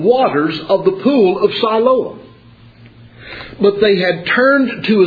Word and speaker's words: Siloam: And that term Shiloh, that's the Hebrew interpretation. Siloam is the Greek interpretation. Or the Greek pronunciation, rather.